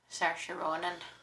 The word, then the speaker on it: Saoirse Ronan: So, I mean, I really liked Saoirse Ronan. – Saoirse Ronan.